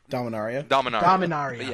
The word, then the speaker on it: Dominaria: Dominaria.